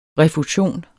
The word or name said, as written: refusion